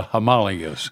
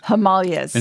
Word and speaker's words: Himalayas: You know, we heard this, we were in northern India, they call it the Himalayas. – Himalayas. – Instead of the Himalayas. – Yes. – So we don't put the accent on the right syllable. – Right. – Or pronounce it quite right.